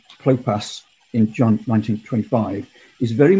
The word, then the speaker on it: Clopas: Mary, the wife of Cleophas, or Clopas, as it would be better pronounced.